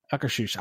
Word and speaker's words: Akershus: And you know, prime example, if there wasn't the representatives working in Norway, you wouldn't be able to find out how to pronounce Akershus properly. – Akershus. Oh, I said it right that time, I think. Okay. – Yeah. – Akershus.